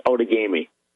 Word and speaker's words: Outagamie: And that's in Outagamie County. Is that right? Welcome to the show. – Well, thanks for having me. Yes, it's pronounced Outagamie.